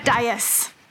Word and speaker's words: dais: Isn't it usually pronounced die? Am I taking crazy pills, or is it usually die? – I think maybe. I don't know. – Yeah. It's. – But they said dais.